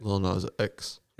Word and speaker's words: Lil Nas X: Lil Nas X. – Some people say the X and some people don't, from what I've understood. – I don't know. – Anyway, I'm not a big Lil Nas X guy, but then there's the other version that has, what's his name in it? – The yodeling kid?